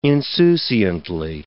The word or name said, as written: insouciantly